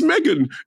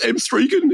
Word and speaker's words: M3GAN: why does he keep calling it M3GAN? It's pronounced Megan. It's not pronounced M3GAN.